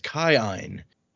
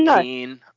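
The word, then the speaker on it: Kiin: Fun fact before we get into it, this her uncle is the first character we've met with the double I, which is pronounced according to the pronunciation key as I. So his name is Kai-ein.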